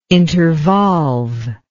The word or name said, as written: intervolve